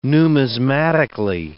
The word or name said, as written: numismatically